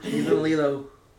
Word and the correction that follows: Lille: Wait, let me see. Yeah, he's in Lille.